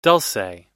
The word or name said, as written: dulce